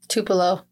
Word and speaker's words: Tupelo: So motorists discovered Nancy Carol Stevens' body on a roadside in, how do you say it, Tupelo? – Tupelo.